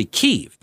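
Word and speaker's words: Kyiv: He says apparently it's based on human intelligence and concerns Russia's play for Kiev, which, by the way, uh, you hear two different pronunciations. Like I grew up always hearing it was Kiev, but it's actually apparently Kiev. That's the way the Ukrainians pronounce it. Kiev is the Russian pronunciation. And so I'm not going to do Russia any solids here, so it's Kiev.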